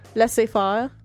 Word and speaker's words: laissez-faire: Laissez-faire.